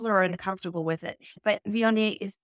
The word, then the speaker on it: Viognier: But we do pronounce it Viognier. So the yay at the end, yay Viognier. Now, let's talk about a classic style of Viognier from the Northern Rhone.